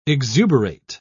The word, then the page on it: exuberate: British English